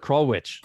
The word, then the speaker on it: Krulwich: We mentioned it on the intro, and that's the one that Robert Kroll, am I saying his name correctly? Is it Krollwich? – Yeah, Robert Krollwich.